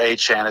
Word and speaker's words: Etienne: And they already, you know, just signed Travis Etienne, and so, or Etienne as he pronounces it.